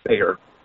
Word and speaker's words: Thayer: So just try Sayer. See where I fall on your calendar. Yeah, it's spelled with a T. T-H-A-Y-E-R. – You spell Sayer with a T-H. – T-H-A-Y-E-R. Sayer.